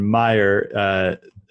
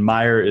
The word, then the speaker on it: Meijer: So it's Henry, and then Meijer is, so Henry is spelled H-E-N-R-I, and then Meijer is spelled M-E-I-J-E-R. That's how you spell it. Spelled again, H-E-N-R-I-M-E-I-J-E-R.